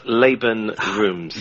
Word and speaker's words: Laban Roomes: Uh, it's actually pronounced Laban Roomes.